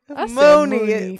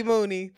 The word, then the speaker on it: Mooney Long: What'd you say? Say her name one more time. – I don't know. Money Long? What is that child's name? – Mooney. Forever, it will be Mooney.